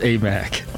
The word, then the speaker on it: Aimaq: Fardaz Amak.